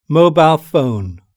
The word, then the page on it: mobile phone: British English